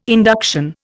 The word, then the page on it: induction: Indian